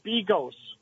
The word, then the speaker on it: Bigos: And that's pronounced Bigos. – Bigos, thank you.